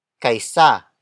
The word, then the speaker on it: kaysa: The next word is kaisa. Kaisa. Kaisa. Kaisa is spelled as K-A-Y-S-A.